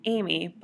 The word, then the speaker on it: Amie: It's Amie, but it's spelt a little differently. It's A-M-I-E. So across my life, I've come into people who refer to me as Annie, Arnie, and all different types of Amie when they're reading it.